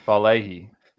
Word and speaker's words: Vailahi: Is that how you say it? Yeah. Vailahi.